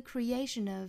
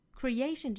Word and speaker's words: creation: Lies in the creation of illusion. Creation 这个词重读在这个字母 A 上，所以说应该读成 creation. Creation, 大家不要读成了 creation. Creation of, 连读 illusion. Illusion 这个词 S-I-O-N, 这里读成一个浊辅音 r, 也就是由 sh 变成了 r.